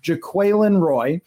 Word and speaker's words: Jaquelin Roy: It is pronounced Jaquelin Roy.